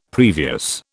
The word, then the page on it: previous: British English